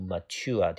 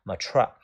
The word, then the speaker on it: mature: 第一个单词叫做 mature，这个单词的发音有两种，第一种读音读 mature，第二种读音读 mature。然后我个人十分不喜欢 mature 那个单词，我喜欢读 mature，它的意思叫成熟的。